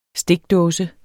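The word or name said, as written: stikdåse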